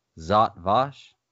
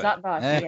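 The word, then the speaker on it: Zhat Vash: Is it Zot Vash? – Yes. – I thought, very yes. – good. – Zot Vash.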